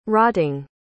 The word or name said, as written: rotting